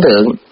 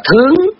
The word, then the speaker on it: thướng: Mà ở cái nghĩa động từ thì họ đọc là thướng, thướng là đi lên, mà nghĩa trạng từ là ở trên. Một chữ đó thôi, nhưng mà khi mà nó là trạng từ mình đọc là thượng, mà khi nó là động từ mình đọc là thướng.